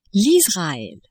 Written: Israel

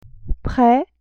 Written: près

